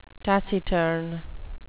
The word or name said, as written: taciturn